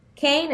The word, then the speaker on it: Kean: Supera se escribe a sí mismo como suena, S-U-P-E-R-A. Kane es el nombre de la universidad, K-E-A-N.